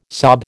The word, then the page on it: sob: American English